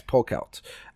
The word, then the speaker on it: Polkelt: Next up, Lorekeeper Polkett. Polkett? I don't know how you pronounce it. Polkelt? – Polkelt, I think. – Yes, Polkelt.